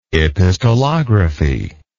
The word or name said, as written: epistolography